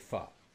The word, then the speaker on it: pho: It is pronounced pho, by the way.